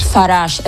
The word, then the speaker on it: Farage: ¿Cómo se pronuncia? Farage, ¿cómo es? – Farage. Es que suena tan francés que es curioso que el hombre sea tan antieuropeísta.